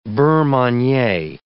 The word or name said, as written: beurre manie